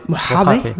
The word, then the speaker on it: Mojave: macOS Mojave, Mojave, ou je sais pas comment tu prononces ça. Mojave.